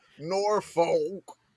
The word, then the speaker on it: Norfolk: He got dragged so hard in our timeline for saying Norfolk, hitting that L real hard. – Norfolk. – So listen, folks, the name of the school is Norfolk State University. – That's right. – The F-O-L-K is pronounced fuck. – Yep. – So yes. Yes. – That's not a profanity. That's just the actual pronunciation.